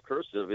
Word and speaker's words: cursive: The point is, the first syllable of cursive is curse.